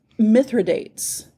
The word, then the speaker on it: Mithridates: It looks like it's going to be Mithril, but it's Mithridates, which is what I thought, but apparently not. – I want it to be Mithridates.